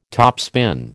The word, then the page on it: topspin: American English